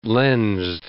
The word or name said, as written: lensed